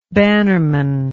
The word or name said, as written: bannerman